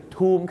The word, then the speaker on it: Tomb: บางคนนะครับไปออกเสียงว่า Tomb Raider ถ้า Tomb Raider ก็ผู้หญิงขี่ทอมถ้า Tomb Raider ก็ผู้หญิงขี่สุสานแล้วก็จะบุกไปขี่สุสานทำไมครับหลายคนนะครับงงไงครับเพราะว่า BOMB ก็คือบอมพอเห็นคำว่า TOMB ก็เลยนึกว่าทอมไม่ใช่นะครับเห็นไหมครับไม่มีมาตรฐานครับภาษาอังกฤษ Tomb ครับเหมือนคางทูมน